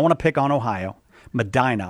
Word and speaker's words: Medina: If it's supposed to be pronounced Medina, I apologize. I never get those right. – It's pronounced Medina. – Ah! – Everyone else calls it Medina. – Yeah, like a normal person, but not a person from Ohio. Okay, yeah.